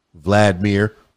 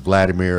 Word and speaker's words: Volodymyr: Ukrainian President Volodymyr Zelensky, I guess that's pronounced Vladimir as well, Volodymyr Zelensky, responded to Biden's earlier remarks by tweeting that, quote, there are no minor incursions, end quote.